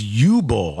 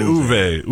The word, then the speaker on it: Uwe: How do you say his name? I thought it was U-Bull. I think I've said, I'm probably screwed that up forever if that's how you actually say it. – I watched a couple videos and like the Uwe? German pronunciation. Somebody said Uwe. Uwe Boll. Okay. Right. Looks like Yui.